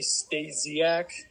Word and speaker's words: Stasiak: No, you said it right. Stasiak. There's a lot of ways to say it right. – But you don't correct people or like prefer one way. – No, I say Stasiak because people call me Stasi or Stays, so correct. It works.